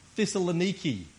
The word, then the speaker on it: Thessaloniki: In the north of Greece, the city of Thessaloniki is how they pronounce it these days, apparently.